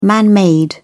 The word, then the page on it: man-made: British English